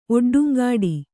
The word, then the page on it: ಒಡ್ಡುಂಗಾಡಿ: Kannada